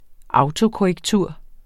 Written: autokorrektur

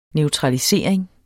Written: neutralisering

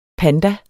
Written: panda

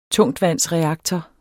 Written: tungtvandsreaktor

